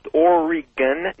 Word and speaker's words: Oregon: I'd first like to tell everybody it's pronounced Oregon, not Oregon.